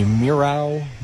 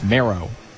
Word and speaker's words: Mierau: Back to the top of the order for Max Mero. Mero is spelled M-I-E-R-A-U. Talking to his dad earlier, he said, basically no one gets it right. You would think it'd be Miro, but it's marrow, like bone marrow.